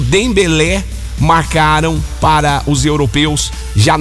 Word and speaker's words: Dembélé: E também do Dembélé. Falei certo também, Dembélé.